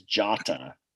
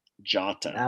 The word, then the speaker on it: Jota: It's Jota. – All right. Chris gets 3 points. Kyle gets 2. It is Jota. Jota. – Okay. – Not Jota. It's Jota.